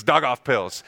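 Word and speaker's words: Daugavpils: And that's how you pronounce it. It's Dog Off Pills.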